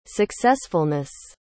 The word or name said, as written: successfulness